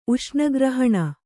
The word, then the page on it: ಉಷ್ಣಗ್ರಹಣ: Kannada